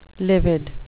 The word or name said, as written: livid